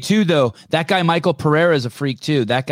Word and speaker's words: Pereira: Maybe I said his name wrong. Maybe I think it's Alex Pereira. – Alex Pereira. Thank you.